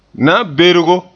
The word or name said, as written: na'anbergo